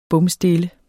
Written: bomstille

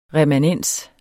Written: remanens

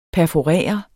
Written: perforere

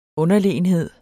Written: UNDERLEGENHEDEN

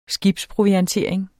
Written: skibsproviantering

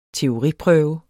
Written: teoriprøve

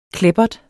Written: kleppert

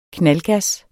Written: knaldgas

